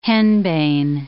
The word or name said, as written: henbane